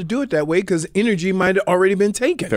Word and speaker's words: NRG: What am I supposed to pronounce it? Energy. – Yeah, that's the company. It's NRG. – But is it? But do they? – Energy.